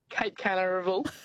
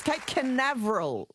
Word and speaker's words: Cape Canaveral: Cape Canaveral. – I think you've Googled it, but you haven't exactly... pronounced it properly, but yes, Cape Canaveral it's called.